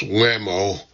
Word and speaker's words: WHMO: Guides and other staffers in uniform are assigned to the White House Military Office, WHMO, pronounced WAMO, runs all hospitality and food services.